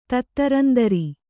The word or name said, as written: ತತ್ತರಂದರಿ